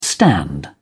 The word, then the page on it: stand in: British English